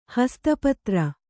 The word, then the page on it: ಹಸ್ತಪತ್ರ: kannada; hasta patra